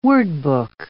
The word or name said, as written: wordbook